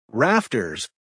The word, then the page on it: rafters: American English